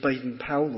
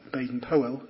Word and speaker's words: Baden-Powell: Motto of the scouts, using the initials of Baden-Powell, or Baden-Powell, as it should be pronounced, who founded the scouting movement.